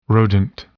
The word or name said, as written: rodent